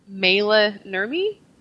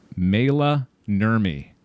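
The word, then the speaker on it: Maila Nurmi: I would say Mayla Nurmi. – Mayla Nurmi. Yeah, I think you're right.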